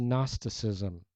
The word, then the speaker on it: Gnosticism: And the prevalent philosophy in Greek culture at this time was Gnosticism, with a silent G on the front.